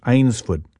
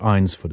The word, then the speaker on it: Eynsford: It was called Eynsford, not Eynsford. It's one of those English words that doesn't look like how it's said.